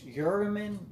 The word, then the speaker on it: Urim: I think it's Urim, U-R-I-M, and T-H-U-M-M-I-M.